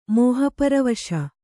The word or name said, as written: ಮೋಹಪರವಶ